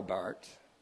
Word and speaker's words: Barth: So Barth, that's Karl Barth, I'm not speaking here about Bart Simpson. Some of you think so. Karl Barth, B-A-R-T-H, Swiss pronunciation. He was a Swiss theologian. Is Barth, not Barth.